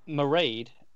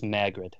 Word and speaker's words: Mairead: Mairead is the Gaelic pronunciation, the original spell, the pronunciation of that name. – Mairead. – However, All right. – she has tweeted in the past that she goes by the Americanized saying of it, which is just Maegrid. – Maegrid. – Yes. – Yeah, rhymes with air. So Maegrid.